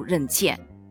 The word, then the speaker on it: 任茜: 由于这个字呢是一个多音字，在她首次亮相国际赛场上的时候，这个字读成茜，所以呢就一直延续下来读任茜。